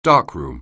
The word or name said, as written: darkroom